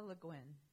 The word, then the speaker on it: Le Guin: Le Guin, L-E-G-U-I-N, I don't know how to pronounce that, just passed away, and apparently she was an excellent sci-fi writer. – Ursula Le Guin. – Le Guin, thank you.